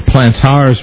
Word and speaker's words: plantar's: The word is a reference to the feet, and it's spelled T-A-R instead of T-E-R. So plantar's warts.